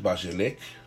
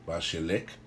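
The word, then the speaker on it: Beshalach: Muy bien, entonces parasha número 16, Beshalak, Beshalak o Beshalak, se puede pronunciar igual, Beshalak, Beshalak, y significa cuando dejó ir.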